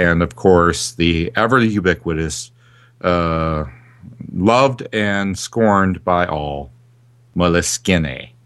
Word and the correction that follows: Moleskine: Oh, don't say it like that. – Moleskine. – There you go.